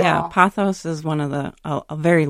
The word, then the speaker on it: Pothos: Patrick writes, this is a photo of me at grandma's house, the late 1990s, with her, so am I pronouncing this right? Pothos in the corner? – Yes.